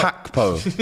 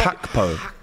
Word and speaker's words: Gakpo: Isn't pronounced a G. – Is it a Daily Mail? – Hakpo. It's Hakpo.